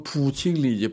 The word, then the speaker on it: 보경리: 지금 상하이 위성목 기념관으로 되어 있는 집이 사, 보경리 중국 발음은 부칭리 이제 부칭리 사호예요.